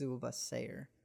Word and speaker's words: Zul-Bha-Sair: Yeah, I keep wanting to put a shh on it, but I think it's Zulbasir. Zulbasir. There's an H in there. – Well, I think that H is just for, it's a silent H.